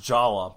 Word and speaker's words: Jaller: I think his name is actually still pronounced Jala, based on Mask of Light.